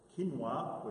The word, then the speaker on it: quinoa: Quinoa, which is the correct way to pronounce that, quinoa is not on the list, and therefore it's kosher, period.